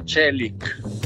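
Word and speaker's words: Çelik: Si pronuncia Celic, no Celic, Celic, che significa acciaio.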